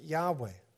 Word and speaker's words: Yahweh: Greek transcriptions also indicated that YHW, or Yod He Wow He, should be pronounced Yahweh.